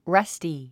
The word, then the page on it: rusty: rʌ́sti; ラスティ